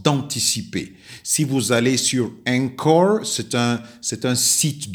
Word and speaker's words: Anchor: Euh, vous allez, euh, le titre c'est Réussir son anglais sur euh, le site Encore. Encore s'écrit A-N-C-H-O-R.